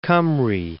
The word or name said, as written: Cymru